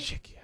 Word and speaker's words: Czechia: Czechia. That's how it's said on the website there.